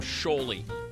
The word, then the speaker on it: Sholey: Rhymes with Holy. His last name, Sholey.